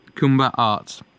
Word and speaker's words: Kuumba: And Kuwumba is spelt K-U-U-M-B-A. – Kuwumba Arts. I'm not putting enough of an ooh in it when I say it. Like, there's more of an ooh when you do it.